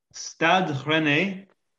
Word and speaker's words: Stade Rennais: Stade Rennais.